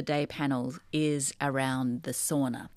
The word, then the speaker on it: sauna: We pronounce it sauna. That's the correct the correct pronunciation.